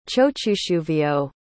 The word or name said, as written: chochuschuvio